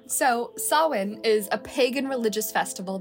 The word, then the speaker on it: Samhain: The word is spelled S-A-M-H-A-I-N. And you would think that it's pronounced Samhain. It is not. It's pronounced Samhain because Celtic is a language that says fuck you to consonants.